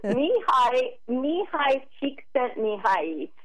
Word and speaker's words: Csikszentmihalyi: Mihaly Csikszentmihalyi.